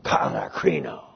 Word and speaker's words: katakrino: Sounds like katakrino. K-A-T-A-K-R-I-N, long O, katakrino.